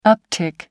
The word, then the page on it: uptick: American English